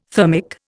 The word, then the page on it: thermic: Indian